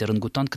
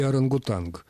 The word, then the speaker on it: орангутанг: И вот этот «анг», он как раз больше соответствует тому, как этот звук произносится в исконном языке. – Значит, можно и «орангутанг».